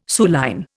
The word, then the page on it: suilline: Indian